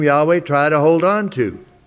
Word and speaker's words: Yahweh: they show this name is and has to be the name that is pronounced Yahweh. It can't be anything else. It is Yahweh.